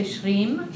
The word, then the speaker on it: shreem: So shreem, can you say shreem? – Shreem. – And it's a long I as well, shreem.